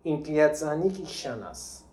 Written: Inkliazanikshana

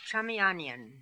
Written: Chamianian

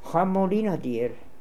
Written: Hamolinadir

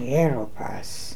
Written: Heropass

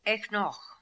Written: Eknokh

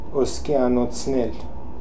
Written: Oskianotznel

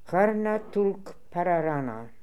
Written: Harnatoolkpararana

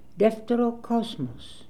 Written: Defterocosmos

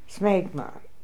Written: Smegma

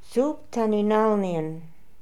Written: Sooptaninalnian

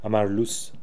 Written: Amarloos

